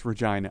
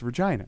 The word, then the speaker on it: Regina: It's, and it is pronounced Regina. I'm totally serious. You talk to someone from Canada, from Saskatchewan, and you say, you know, you're from Regina, and they'll be offended. They'll say, no, it's Regina, and that's how it's pronounced.